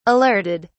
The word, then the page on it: alerted: British English